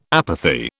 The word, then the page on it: apathy: British English